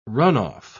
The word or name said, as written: runoff